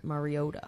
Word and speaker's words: Mariota: You got the pronunciation right on the second try, too. – Mariota. – Actually, though, I don't mean to, like most people say his name wrong, so that's actually really common.